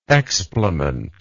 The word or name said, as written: explement